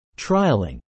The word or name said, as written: trialling